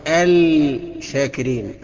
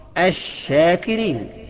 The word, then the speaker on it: الشاكرين: بعد كده الشين الشاكرين، بإدغام اللام في الشين برضو، الشاكرين، النطق الخطأ بتاعه الشاكرين، الصح الشاكرين.